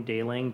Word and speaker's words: Dailing: Then you can either search for my name, Dailing, D-A-I-L-I-N-G, or Laleem, spelled like La-Lime.